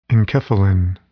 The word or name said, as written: enkephalin